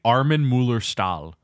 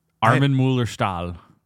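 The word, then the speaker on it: Armin Mueller-Stahl: His name is actually Armin Müller-Stahl. Armin Müller-Stahl.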